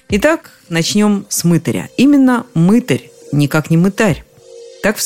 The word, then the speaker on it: мытарь: Обратите внимание на ударение. На «ы» оно приходится только в слове «мытарь». Во всех остальных переходит на «а» – «мытарить», «мытариться», «мытарство».